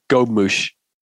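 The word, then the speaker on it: gobe-mouche: I just clicked on a thing that says pronunciation, and it says gobe-mouché. Gobe-mouché. – Oh. – Gobe-mouché. – Gobe-mouché. – Well, that makes sense.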